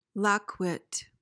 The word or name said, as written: lackwit